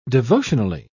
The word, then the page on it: devotionally: British English